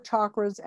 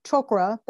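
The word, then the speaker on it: chokra (चक्र): Now, just to remind you, chakra or chokra, that's how they say it in Sanskrit, excuse me, means wheel or vortex.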